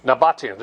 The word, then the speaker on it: Nabataeans: Built by the, I can't say the word, Nabobians? Not the Maccabees. No, Nabobians or something like that. I can't say it right. Go say it. – Nabataeans, there you go.